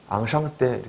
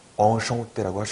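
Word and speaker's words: enchanté: 엉샹떼, 예, 만나서 반갑습니다 할 때 엉샹떼, 예, 앙샹떼 이렇게 하시면 안 돼요. 예, 그렇게 배우시면 안 되는 거예요. 엉샹떼라고 하셔야 되는 거예요. 예, 발음 앙샹떼로 배우셨으면 발음 교정해 주세요. 꼭 엉샹떼라고 하셔야 돼요.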